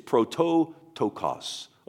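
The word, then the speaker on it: prototokos (πρωτότοκος): So the word firstborn, let me see if I can pronounce it phonetically in the Greek, okay? Because you know that I don't know Greek, but it's prototokos, okay?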